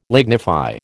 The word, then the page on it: lignify: American English